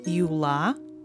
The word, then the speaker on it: Юла: In first case, you have a, Юла, and of course, the last syllable is stressed.